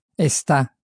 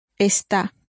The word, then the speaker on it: está: So, the is or it is that we've been using is pronounced está. – Está. – Está. – Está. – And the emphasis is really very much on the está at the end.